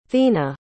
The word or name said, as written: thina